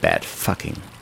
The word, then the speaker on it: Bad Fucking: Vor gut 10 Jahren diente der Ort sogar als Kulisse für die Filmsatire über den fiktiven, abgefuckten Kurort Bad Fucking, englisch ausgesprochen Bad Fucking.